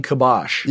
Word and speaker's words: kibosh: So, what do you mean when you say reclaim kibosh? – Yeah, am I saying that right? Yeah, you are.